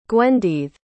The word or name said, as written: gwenddydd